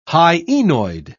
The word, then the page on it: hyenoid: British English